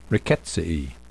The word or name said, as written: Rickettsiae